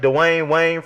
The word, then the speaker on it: Dwayne Wayne: No, you're not saying it right. Like Dwayne Wayne from A Different World.